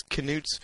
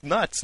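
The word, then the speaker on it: Knuts: I know the Scholastic site says it's Canutes, but when Jo was reading Haploid Prince in Edinburgh, she pronounced it as nuts, no K.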